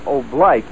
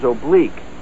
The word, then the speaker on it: oblique: The word is oblique, Sergeant. It's pronounced oblique.